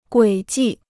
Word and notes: 轨迹: guǐ jì

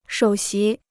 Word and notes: 首席: shǒu xí

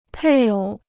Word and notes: 配偶: pèi ǒu

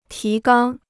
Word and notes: 提纲: tí gāng

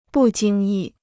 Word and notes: 不经意: bù jīng yì

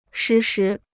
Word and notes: 时时: shí shí

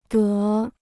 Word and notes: 德: dé

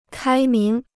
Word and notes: 开明: kāi míng

